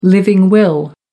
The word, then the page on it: living will: American English